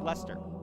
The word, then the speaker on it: Leicester: L-E-I-C-E-S-T-E-R, that's pronounced Lester. H-A-V-E-R-H-I-L-L is pronounced Haverhill.